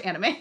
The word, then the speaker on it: anime: So shout out to any of our listeners who watch anime. – I don't want to be a poopy faced tomato nose, but I did take 3 years of Japanese in high school. It's pronounced anime.